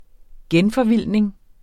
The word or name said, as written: genforvildning